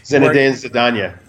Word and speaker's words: Zinedine Zidane: It was like Zinedine Zidane. Is that, I don't know if you say his name. – Zinedine Zidane.